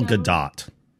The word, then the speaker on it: Gadot: Gal Gadot.